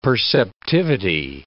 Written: perceptivity

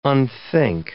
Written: unthink

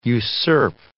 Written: usurp

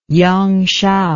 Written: yangshao

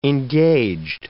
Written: engaged

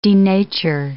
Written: denature

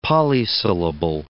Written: polysyllable

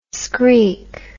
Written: skreek

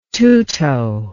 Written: tutto

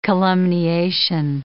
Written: calumniation

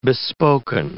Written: bespoken

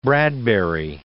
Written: Bradbury

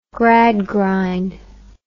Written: gradgrind

